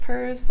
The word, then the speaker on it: pers: Like, I guess it's pronounced pers, like hers, for a possessive pronoun.